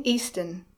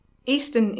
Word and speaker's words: Easton: It's Mrs. Jane Easton. Easton is spelled E-A-S-T-O-N.